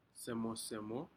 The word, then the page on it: semo semo: Aneityum